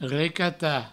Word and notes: recatar: recata

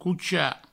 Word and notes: cochar: coucha

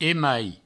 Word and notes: emai: Provençal

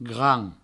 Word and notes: grand: Provençal

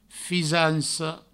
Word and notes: fisanca: fisanco; Provençal

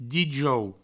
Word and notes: dijous: dijou; Provençal

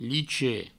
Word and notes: lichet: provençal

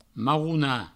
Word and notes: marronar: marrouna

